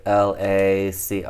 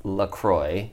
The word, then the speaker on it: LaCroix: And the thing is, it's actually pronounced LaCroix. – Yeah. – Shut the fuck up. – First of all. Not LaCroix, as a lot of people say, or like the pretentious people who are drinking it say.